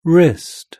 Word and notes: wrist: British English

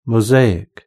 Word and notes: mosaic: British English

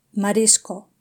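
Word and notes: marisco: European Spanish

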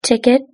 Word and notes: ticket: British English

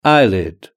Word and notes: eyelid: British English

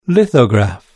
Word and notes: lithograph: British English

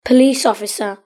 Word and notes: police officer: British English

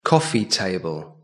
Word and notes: coffee table: British English

